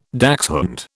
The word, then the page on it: dachshund: British English